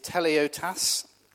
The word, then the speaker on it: teleiotes: The Greek word there is used is teleiotes, which is spelled T E L E I O T E S.